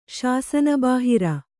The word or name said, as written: ಶಾಸನಬಾಹಿರ